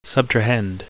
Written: subtrahend

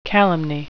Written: calumny

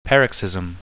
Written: paroxysm

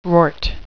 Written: rort

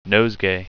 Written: nosegay

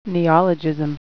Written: neologism

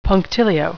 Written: punctilio

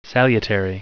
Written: salutary

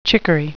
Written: chicory

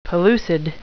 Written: pellucid

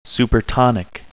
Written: supertonic

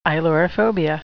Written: ailurophobia

